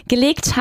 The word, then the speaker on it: gelegt: Los papeles, gelegt habe, geht nicht auf. Die alte Kommode, in die ich die Unterlagen gelegt habe, geht nicht auf. ¿Vale? Pronunciamos ese gelegt con una E bastante alargada, ¿vale? Gelegt. Muy bien.